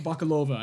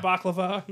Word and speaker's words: Bakalova: It's Bakalova, I think? – Bakalova, I believe is how you say it. – It's not Baklava.